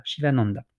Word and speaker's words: Sivananda: C'est un yoga qui a été fondé par, alors comment ça s'écrit, pardon S-I-V-A-N-A-N-D-A, Shivananda.